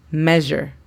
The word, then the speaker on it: measure: Measure, que significa medir. Measure. Nota cómo después de la M se escucha que tenemos la E, cuando en realidad en inglés se escribe EA.